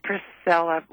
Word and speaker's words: Priscilla: Did I get your name right? – No, it's actually Priscilla with a P.